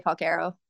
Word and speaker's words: Falcaro: That's Falcaro. The right, proper actually way to say it, the Italian way to say it, but I usually say Falcaro.